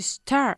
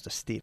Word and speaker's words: stir: To stir. – To stir. Again? – Stir. – To stir. Muy bien. Stir. Y nunca decimos to steer, ¿ok? To steer, by the way, spelt S-T-E-E-R, means? – Girar el volante.